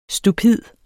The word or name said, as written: stupid